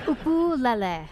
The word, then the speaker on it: ukulele: Peter's first question to Taimane, how the hell do you pronounce ukulele? – Ukulele. – Ukulele. – Uku. – Uku. – Lele. – Lele. – You got it.